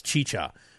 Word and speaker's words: Jeeja: It's spelled J-E-E-J-A-Y-A-N-I-N, but it's pronounced Chicha. So it's mm-hmm. – J-E-E-J-A, and then the last name is Y-A-I-N.